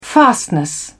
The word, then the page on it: fastness: British English